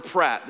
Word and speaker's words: Pratt: In fact, there's a scene in the movie where Chris Platt or Pratt, does anybody know? Pratt, P-R. See, I said it wrong earlier, and I think I'm gonna get an email just for saying the wrong name, but whatever, that's fine.